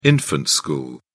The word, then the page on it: infant school: British English